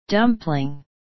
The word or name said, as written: dumpling